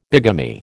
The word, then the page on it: bigamy: American English